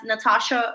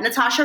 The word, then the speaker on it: Natasha: So you can search me as Natasha Rumbos. My name is spelled with an H after the T, so it's a silent H. So Natasha Rumbos, and I recommend my favorite song, like the one that's like popping, is Menea.